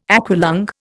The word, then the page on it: aqualung: Indian